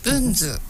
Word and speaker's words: 文図: これこのまま文図って読んでいいんですよね。 – 読んでください、はい、文図、